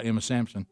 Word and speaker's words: Sansom: Yeah, it's actually a statue of a little girl from the Civil War called Emma Sansom. – Oh, okay. – Or if you want to say it right, Emma Sampson.